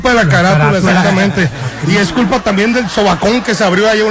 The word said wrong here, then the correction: socavón: Canícula, y no se dice sobacón, se dice socavón.